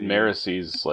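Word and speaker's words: Marasi: Is that how you say her name? – That's how I say her name. That's how I believe it should be pronounced. That's not how the audiobook does it. – Okay. So, Serini is 70. Marisie is rated higher than Wayne. Notable. – Would you like me to break down the etymology for why I think her name should be pronounced that way? – Yeah, sure. – Okay, well, it's derivative of Mare, Kelsier's wife. – Oh. – So, Mare.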